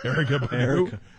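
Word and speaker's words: Erykah Badu: Icky Icky Badu. I don't know who the hell that is. – Erica Badu. Oh, she's been around for a while.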